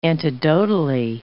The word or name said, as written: antidotally